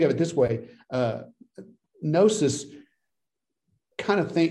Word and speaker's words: gnosis: And the other, which is probably the one we're more familiar with, gnosis, starts with a G, but it's pronounced gnosis, that was more experiential learning, learning on the fly, learning through doing.